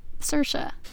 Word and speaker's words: Saoirse: S-A-O-I-R-S-E. And it's pronounced Saoirse.